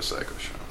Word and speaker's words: Pshow: Welcome to the Psycho Show. – Psycho and show with the silent P.